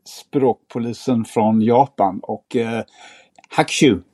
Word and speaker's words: Hakushu: Eh, vi pratade om hur man uttalade, ja jag vågar knappt göra det, hakushu. – Ja, typ, det är kanske, typ så. Ja. Kanske. Hakushu. Ja. – Hakushu, ja. Exakt.